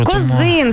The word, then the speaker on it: Козин: Козин все-таки. – Козин. – Ну, принаймні, так наголос нам підказує Вікіпедія.